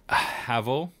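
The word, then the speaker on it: Haval: So Havel, I think is the right way to pronounce it.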